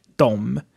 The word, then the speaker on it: dem: Dem is mostly pronounced like dom, like its subject counterpart, de. But you write D-E-M.